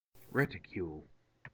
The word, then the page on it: reticule: American English